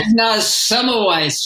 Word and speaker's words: Semmelweis: Ignaz Semmelweis, yes.